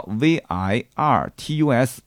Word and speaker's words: Virtus: 它在海外呢，英文名叫 V I R T U S，应该怎么读啊？Virtus 是吧？Virtus，Virtus，Virtus。